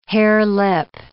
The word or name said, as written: harelip